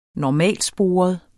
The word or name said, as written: normalsporet